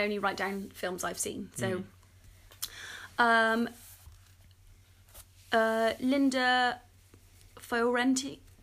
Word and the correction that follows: Fiorentino: Thank you, Fiorentino. Is that right? – Yeah.